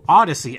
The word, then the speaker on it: Audacy: including the new Odyssey app, which is spelled A-U-D-A-C-Y.